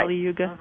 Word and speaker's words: Kali Yuga: Kali Yuga? – Mm-hmm.